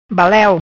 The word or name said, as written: Baleu